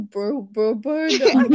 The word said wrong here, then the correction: Bo Burnham: Bo Burnham, I have known about Bo Burnham since.